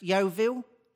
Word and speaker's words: Yeovil: Just one thing, it's pronounced Yeovil.